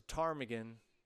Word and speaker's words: ptarmigan: The PTAR was a ptarmigan. Mm-hmm. It was a bird, but it's spelled with a silent P.